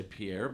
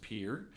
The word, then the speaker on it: Pierre: So the very often mispronounced Pierre, South Dakota. Sometimes people say Pierre, but it's pronounced Pierre.